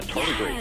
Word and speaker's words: tardigrades: Yeah, tardigrades.